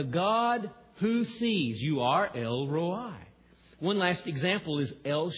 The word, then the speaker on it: El Roi: Another personal name for God is El Roi. El Roi, sometimes the I is pronounced long.